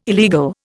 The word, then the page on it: illegal: Indian